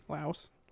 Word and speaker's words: Laos: Well, that's just why you go to Laos. How do you say it? – Laos.